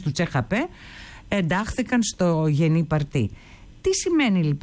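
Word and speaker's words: Parti: Το νέο κόμμα λοιπόν, αυτό το Γενή Παρτί ή Πάρτι, πώς το προφέρουν οι Τούρκοι? – Παρτί. – Παρτί, καλά το φαντάστηκα, ναι, καλά το φαντάστηκα.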